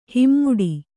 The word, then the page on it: ಹೆಮ್ಮಾಡಿ: hemmāḍi